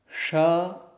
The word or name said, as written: ṣ